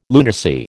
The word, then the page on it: lunacy: American English